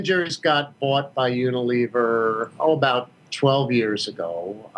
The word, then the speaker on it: Unilever: And is it pronounced Unilever? Is that correct? – Yeah.